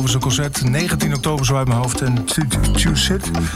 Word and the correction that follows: Tuschinski: kan niet uitspreken. In Amsterdam, een heel luxe theater. Tuschinski. Theater.